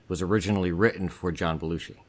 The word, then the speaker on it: Belushi: Oh, Belushi. Belushi Toilet. – It's Belushi? – Belushi, yeah, like the great actor, John Belushi.